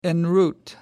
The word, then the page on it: en route: American English